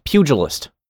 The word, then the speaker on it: pugilist: The G is soft. Pugilist.